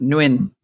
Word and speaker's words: Nguyen: That Vietnamese word, by the way, is pronounced Nguyen.